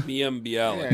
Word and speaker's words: Mayim Bialik: Mayim Bialik.